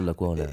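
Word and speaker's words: Lecuona: Lacuna. Ernesto Lacuna.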